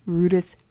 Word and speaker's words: Rüdesheim: Rüdesheim, which many Americans, including me, a few times say Rüdesheim because of the way it's spelled. It's actually Rüdesheim.